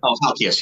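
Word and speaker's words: Halkias: Halkavius. – Halkius, yeah.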